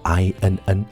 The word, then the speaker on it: inn: 最后啊，我跟大家补充一个生活上非常常见也极其简单的跟 in 有关的词汇，叫做 inn 啊，它还读作 in。